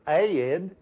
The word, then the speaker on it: Ed: I like going down south because they're the only people I know that could stretch my name into a two-syllable word. Ed.